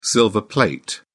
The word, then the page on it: silver plate: British English